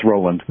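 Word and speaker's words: Rowland: Is it Roland or Rollin? – It's Roland.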